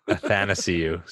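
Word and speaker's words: Athanasiou: NSCU? And like, what do we do about your inability to say his name? – Athanasiu, sorry. – Athanasiu. – Athanasiu, yeah.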